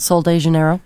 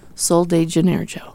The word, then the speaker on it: Sol de Janeiro: and I can't pronounce it, so you say it. – Sol de Janeiro. – Sol de Janeiro.